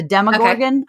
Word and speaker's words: Demogorgon: But there's this big giant monster that he's running away from called I always say it wrong, but it's the Demogorgon, I believe is how you actually say it.